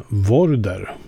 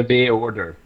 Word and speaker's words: Vorder: Och det är eh, Vorder. Uttalas det så? – Ja, eller B-order. Eller Border.